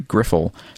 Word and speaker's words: Griffle: From your Northwoods furry Griffle. P.S. The I is short. You say it like Griffin.